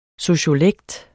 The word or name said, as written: sociolekt